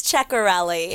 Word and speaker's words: Ceccarelli: It's Ceccarelli.